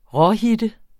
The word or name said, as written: råhitte